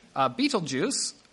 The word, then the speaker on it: Betelgeuse: Betelgeuse, that is actually how you apparently pronounce that one.